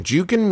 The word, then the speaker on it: Jukin: There is a company, and I believe it's pronounced Jukin Media, or Jukin Media, J-U-K-I-N, who what they do is they buy the rights to viral videos and then distribute them to outlets.